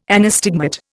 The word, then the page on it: anastigmat: Indian